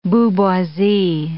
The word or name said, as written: booboisie